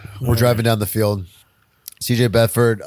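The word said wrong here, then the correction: Beathard: had a really rough start, but Bethard. – I said it wrong, but you're right. It's Bethard.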